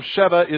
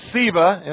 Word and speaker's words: Sceva: Thank you for whoever told me that the correct pronunciation of Sheva is Sceva in Latin.